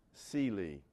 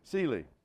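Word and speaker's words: Selah: And you see them, you know, as we go through, it's S-E-L-A-H, you see those words. It's actually pronounced Seely. Like color purple, you remember the, yeah, okay. Seely, that's the way it's pronounced.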